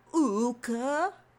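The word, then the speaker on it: Oocca: I've always pronounced it as Ooka, because it's O-O-C-C-A, but it is a mother figure, bird, but it's definitely humanoid head into...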